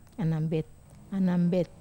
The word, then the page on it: anambet: Wamesa